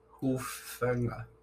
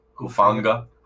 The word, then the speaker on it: Hufanga: Then they finally get a safety at, don't know how to say this dude's name, Talanoa. Sure. – Hufanga. – Hufanga. – Hufanga.